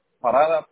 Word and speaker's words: Parada: Parada, P-A-R-A-D-A. – P-A-R, I'm sorry, you said P-A-R-A-D-A? – Uh-huh.